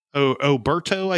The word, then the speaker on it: Oberto: My absolute favorite brand is the Oberta. Oberto, I think is how you pronounce it.